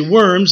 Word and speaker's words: Worms: Well, there was a large conference in Germany, in a town called Worms, spelled W-O-R-M-S. We would say Worms. They pronounce the W as a V, so it's Worms.